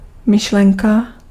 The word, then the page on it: pensée: [pɑ̃.se]